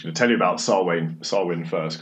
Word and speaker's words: Samhain: I'm going to try and pronounce this right, Samhain. It's sort of spelled Samhain, it's a Gaelic word, but Samhain.